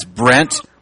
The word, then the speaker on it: Brent: It's Brent Westwood.